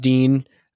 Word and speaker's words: Digne: Lucas Dean. And I started pronouncing it Dean because the guys from Toffee TV asked him and he said that's how you pronounce it.